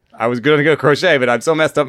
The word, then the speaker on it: Crochet: Garrett Crochet. – Crochet. Nice. Better than the other one, which rhymes with rocket. – you were going to go crotchet?